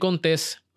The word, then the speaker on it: Contés: Mi nombre es Miguel Contés, con acento en la E, y nos vemos la próxima.